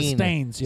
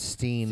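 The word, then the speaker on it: Berenstain: Is it Steen, Steins, or Stains? – It's not Stain, it's Steen.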